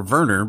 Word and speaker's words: Werner: But Carl Weathers and Werner, I don't know if you would pronounce his name Werner or Werner, because his last name is Herzog. So I'm going to pronounce the W like a V, because that's what they do in like the German language.